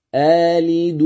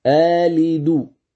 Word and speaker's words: آلد: الوجه الثاني إبدال الثانية ألفا مدية قصرا، يعني بمقدار حركتين، فقول آلد وأنا عجوز، آلد آلد، كأنك تقول خالد، فتمد الهمزة بمقدار حركتين ثم تنطق باللام المكسورة. إذن